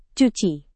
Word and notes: cuci: Indonesian

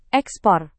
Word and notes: ekspor: indonesian

